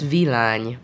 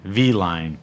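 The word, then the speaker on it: Villány: I pronounced this wrong, so correct me. Vilani? How do you pronounce it? It's Vilagne. – Vilagne.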